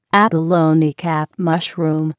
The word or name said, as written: abalone cap mushroom